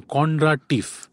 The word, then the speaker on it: Kondratiev: Kondratief.